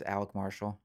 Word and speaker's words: Alec Marshall: My social media is Alec Marshall. And can you spell that for us? – A-L-E-C, like Baldwin. M-A-R-S-H-A-L-L.